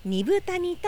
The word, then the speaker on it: 二風谷: ている関根健二さんが住んでいるアイヌの里平取町二部谷にやってきました豊かな自然緑の森に囲まれ猿川が流れる中アイヌの伝統が色濃く残る地域です漢字では 2 つの風の谷と書いて二部谷と読みます、